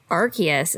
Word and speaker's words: Arceus: Well, they also obviously showed off Arceus is how they were saying it.